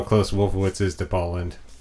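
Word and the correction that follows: Lynde: Is it Lind? – Yes. – Oh, I've Paul been Lind. Saying his name wrong for the last.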